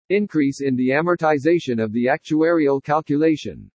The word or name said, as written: INCREASE IN THE AMORTIZATION OF THE ACTUARIAL CALCULATION